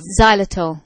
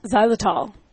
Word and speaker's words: xylitol: What is, how do you pronounce it? Zictal, whatever. That's the substance in gum that is very, very poisonous. Xylitol. – Xylitol. – Yeah. That's it.